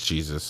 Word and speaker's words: Jesus: It clearly is written Jesus. – You're white. You don't know how to pronounce it. It's Jesus.